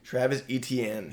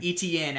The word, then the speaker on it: Etienne: Travis Etienne. – Etienne.